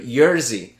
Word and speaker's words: Jerzy: Oh, no, not Yuri. Jerzy. So it's spelled like a jersey. J-E-R-Z-Y. – J-E-R-Z-Y. Z-Y. – And then Kaczynski is like... – K-O-S-I.